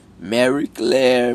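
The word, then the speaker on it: Marie Claire: Usando pseudônimos, três mulheres deram depoimentos à revista Mari Clary. É assim que pronuncia? Não é, um, é assim que pronuncia? Mary Claire.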